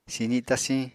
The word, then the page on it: sizi tasiñ: Apatani